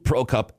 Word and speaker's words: Prokop: Does it matter that Luke Prokop is...